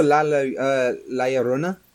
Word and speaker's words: La Llorona: But La Llorona? Fucking correct me, please. – Oh, La Llorona? – Yes, thank you.